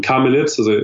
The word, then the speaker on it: Carmelite: And he led us to the National Shrine of Kazakhstan, where we met some wonderful sisters of Carmelites.